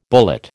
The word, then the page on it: bullet: American English